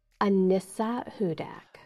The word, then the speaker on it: Anissa Hudak: Anissa Hudak. – Anissa Hudak. H-U-D-A-K.